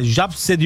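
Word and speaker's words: Zabrze: Fenerbahçe dün akşam Şampiyonlar Ligi ikinci ön eleme turu ilk maçında Polonya'nın Gornik takımını Gornik Zabirse, Japse diye okunuyor.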